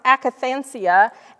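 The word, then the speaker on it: akatharsia: Now, this is a Greek word that's pronounced akathansia, and it's anything that defiles or contaminates.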